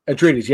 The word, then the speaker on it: Atreides: I think at some point, because when you brought up Dune, House of how do you say it again? Atreides? – Atreides, yeah. Okay. Atreides for Atreides, yeah, something like that.